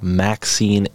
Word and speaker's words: MXene: So essentially, so it says here, it says ultra thin spray applied Maxine antennas. It's spelled MXENE.